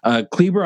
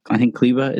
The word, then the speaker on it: Kleber: Kleber, I thought was Kleba, I think Kleba is how you say it.